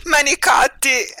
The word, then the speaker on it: manicotti: How do you say manicotti in, though we shouldn't be talking about carbs, how do you say it in Italian? – Manicotti. – See, it just sounds so much better when you say it.